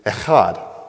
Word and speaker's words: echad: So the Hebrew word for one in Deuteronomy 6:4 is echad, which is E-C-H-phlegm-A-D, okay? That's how you spell that, all right?